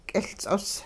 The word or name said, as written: k'iLts'os